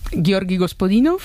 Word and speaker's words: Georgi Gospodinov: A ver, Georgi Gospodinov, sí.